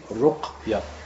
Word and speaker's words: Ruqya: premièrement, il faut bien s'habituer à dire le mot Ruqya. Parce qu'il y a beaucoup de gens qui disent Ruqya. Il y en a qui disent Ruqya. Ils ne savent pas prononcer le mot. On dit Ruqya. Ruqya, avec une, une sur le qaf.